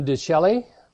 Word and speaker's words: Canyon de Chelly: We have not visited here the Canyon de Chelly.